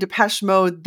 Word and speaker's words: Depeche Mode: Am I saying it right? Depeche Mode. – Right, yes. Depeche Mode.